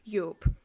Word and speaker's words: Joop: Opa and tante had 3 sons, Piet, Evert, and I'm relying on Emma's pronunciation for this name because I have never seen anything like it in English, Joop.